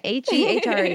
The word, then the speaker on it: Hehre: So my name is Amy, and then my last name is Hehre. – Okay. – Hehre. It's pronounced Hera, like Sarah, but with a H at the beginning.